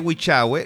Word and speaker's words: Huichahue: Ahora bien, cuando ustedes hablen de Huichahue, les voy a pedir que cuando pronuncien Huichahue lo digan como, ojalá como un tono cuico, como Huichahue.